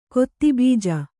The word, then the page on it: ಕೆತ್ತೆಬಜೆ: kettebaje; dial.